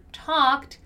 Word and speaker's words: talked: Let's take this last chance here to talk about the pronunciation of the word talked. Okay, even though it's simple past, as you know, the simple past has three different kinds of pronunciation. The one we have here for the word talked is a sound of a T, right?